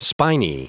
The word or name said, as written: spiny